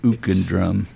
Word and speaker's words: Ook and Drum: When I just met you, I just realized like I've been pronouncing your band name wrong all along, because I'm the guy that says Ook, you know, I figured yeah. It is. But you set me straight here quickly that it's Ook and Drum.